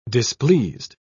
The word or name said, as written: displeased